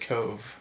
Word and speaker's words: Cobh: It's COBH, but they pronounce it Cove with a V.